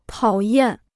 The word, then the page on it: 讨厌: tǎo yàn